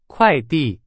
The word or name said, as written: 快递